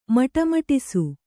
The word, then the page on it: ಮಟಮಟಿಸು: maṭa maṭisu